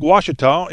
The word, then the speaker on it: Ouachita: Lake Ouachita is in Arkansas. Don't be confused. It's O-U-A-C-H-I-T-A, but pronounced Ouachita.